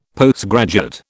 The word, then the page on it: Postgraduate: British English